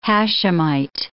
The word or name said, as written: Hashemite